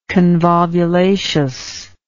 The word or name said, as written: convolvulaceous